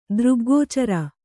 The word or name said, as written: ದೃಗ್ಗೋಚರ